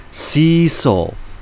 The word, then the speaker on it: Cecil: To add to the persona of suspect Michael Cecil Lee, at his first court appearance, Deputy Prosecutor Herbert Mukai mispronounced his middle name while reading the charges off the court document. After Lee heard his middle name pronounced in the British fashion with a short E, he shouted, "Cecil! Cecil!"